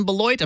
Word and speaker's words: Beloit: So tell us what it's like to serve the saints in Beloit.